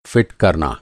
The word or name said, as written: फ़िट